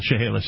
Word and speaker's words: Chehalis: According to the story, it was originally down by the, and I don't know if I'm pronouncing this right, Chehalis River. – Chehalis, yeah.